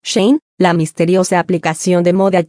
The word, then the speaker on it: Shein: La plataforma que utiliza Sachan pertenece a una marca china llamada Shein, que se pronuncia Shein- que ha atraído rápidamente a un ejército mundial de fans adolescentes en TikTok.